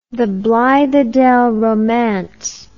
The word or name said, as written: blithedale romance the